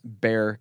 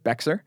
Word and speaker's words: Bexar: In fact, if you guys are listening in the San Antonio Bexar County, B-E-X-A-R is how you pronounce Bexar County. It looks like Bexar, but it's not. It's Bexar County.